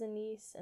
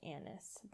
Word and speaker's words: anise: I just looked it up, and the British way is anise and the American way is anise, but I prefer the British pronunciation, so I will stick to it.